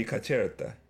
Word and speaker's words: Caserta: You're pronouncing it in Spanish. Let me see if I can find it to pronounce it in Italian. – It's not Spanish. I think that's actually how it's pronounced. – It's Italian. – Caserta. – No, it's not. The pronunciation is different in Italian. Let me see. No, it would be Caserta. – Caserta, okay. With that form. – Yeah. – Oh, yeah. The C is like a ch sound.